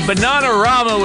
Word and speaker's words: Bananarama: So, it's important to remember that in the Queen's English, the word banana is pronounced with the same vowel sound throughout. So we should pronounce Bananarama that way too, or we'll turn their little joke into a nasal Midwestern horror.